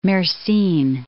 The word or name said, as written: Mersin